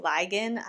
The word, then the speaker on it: Ligon: There was also a clip where Pastor Bill Ligon, I don't know how you pronounce his last name, it's L-I-G-O-N, so Ligon, whatever,